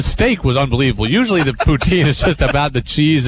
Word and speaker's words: poutine: You had the steak poutine, which is... why do you always laugh every time I say that? – Because you said it wrong. It's poutine.